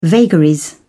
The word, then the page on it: vagaries: British English